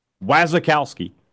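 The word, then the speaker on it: Wasikowski: Wazakowski, there's another syllable in there we've been leaving out. Wazakowski, he liked both of those hires for state.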